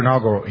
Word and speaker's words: Nagel: Our author, Dr. Nagel, I say that right, Nikki? Thank you. Dr. Nagel, he wrote, writes, "Naturalism, or a pagan worldview, could in fact render naturalism itself irrational.